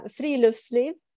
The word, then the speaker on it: Friluftsliv: The word that it's Friluftsliv. Is that how you say it? – That's pretty close.